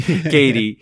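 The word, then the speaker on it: Cady: It just throws me off because it's spelled with the C. I don't know why I look at it and I'm like, I want to say Caddy, but Katie.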